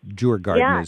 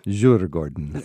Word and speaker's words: Djurgården: Djurgården is how I would mispronounce it. But Djurgården. – Djurgården. – Yes, perfect.